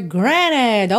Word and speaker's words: granted: Mas uma outra opção de pronúncia é granted. Então, eu até pintei aqui de cinza o T, o que raramente eu faço, só porque realmente, se você ouvir o Richard Marx cantando, você não escuta nem traço desse T, tá?